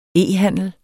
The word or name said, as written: e-handel